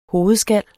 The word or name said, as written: hovedskallen